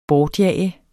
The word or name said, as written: bortjage